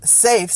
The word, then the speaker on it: SAFe: Now, in the world of SAFe, Scaled Agile Framework, SAF with the silent E, the SAFe framework, they also call that a HIP.